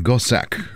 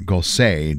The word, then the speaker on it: Gossec: Here's some music by a French composer named François Gossec, or Gosset, depending on your pronunciation.